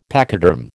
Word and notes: pachyderm: American English

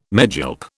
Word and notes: megilp: British English

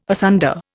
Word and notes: asunder: Indian

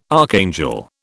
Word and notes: archangel: British English

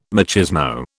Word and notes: machismo: British English

